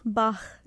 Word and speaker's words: Bach: Yes, Bach. – Because I say I said Bach, but you said Bach. – Bach, yes.